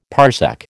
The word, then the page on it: parsec: American English